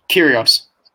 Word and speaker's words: Kyrgios: It's Kyrgios, right? – Kyrgios.